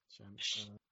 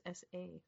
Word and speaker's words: shamisen: Pronunciation of that? – Shamisen. Let me see. – Thank you. – S pronounced SH. – Shamisen. – I mean, it's SH. – Oh, I always thought it was just SA.